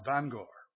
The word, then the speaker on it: Bangor: My people make fun of me in the way in which I pronounce Bangor. Now don't you dare laugh at that. That's a Canadian pronunciation. That's the right way, by the way, but we'll not argue about that.